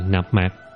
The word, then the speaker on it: Nam Mô: Hạnh là chí thành khẩn thiết, thường niệm Nam Mô, âm đọc là Nạp Mạc A Di Đà Phật, thời thời khắc khắc chớ để tạp quên.